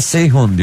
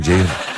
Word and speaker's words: Ceyhun: Bana mesela Seyhun diyorlar. Yes, Seyhun. Öyle bir şey yok diyorum, saçmalama Ceyhun.